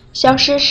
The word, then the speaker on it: 消失: 消失是不见了、突然离开的意思。需要注意的是，消失的声调，消是一声，失也是一声，千万不要和小事这个词弄混。